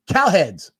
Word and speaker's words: Cowherd: Joe freaking Burrow, if you did not hear, was on Cowherd's. Excuse me, said his name wrong. Cowhead's show.